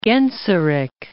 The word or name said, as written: Genseric